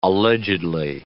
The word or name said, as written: allegedly